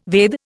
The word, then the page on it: Veda: Indian